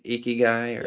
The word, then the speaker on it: ikigai: But you know, you use the term, I may butcher it, it's called ikigai. Is that right? Ikigai? – Yes. – Ikigai.